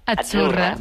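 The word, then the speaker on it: Azzurra: sí, como pizza. La zeta no es la zeta como decimos en catalán tan sonora, no. Es achurra. – Muy bonito, muy bonito. – Es como pizza.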